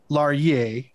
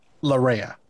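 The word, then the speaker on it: Laryea: Larye. How the fuck did the English get Larea? That is clearly. – Have you seen how the English pronounce things? Come on. – That is clearly a two syllable last fucking name.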